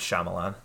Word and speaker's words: Shyamalan: I can say his name. It's Shyamalan, right? – Shyamalan Ding Dong. – Shyamalan Ding Dong. – Yeah, M. Night Shyamalan. – M. Night Shyamalan. Cool.